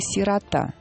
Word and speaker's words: сирота: Потому что именно в связи с этим обсуждением очень часто звучит слово «сирота». И очень часто в нем неправильно ставят ударение. Дорогие наши слушатели, не сомневайтесь, «сирота» только так правильно.